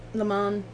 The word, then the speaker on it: Le Mans: No S. No, it's French. There's no S. You just pronounce Le Mans. – There's an S in there. So. – Okay, you say the S. – Le Mans. I'm going by the article. – You just don't pronounce it. It's French. You say Le Mans. You just don't pronounce the S.